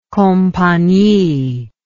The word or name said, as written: compagnie